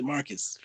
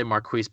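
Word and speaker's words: Marques: People pronounce it Marcus for some reason. So. – Oh, I heard someone say Marquise Bolden on the broadcast and it shook me. I was like, what the heck?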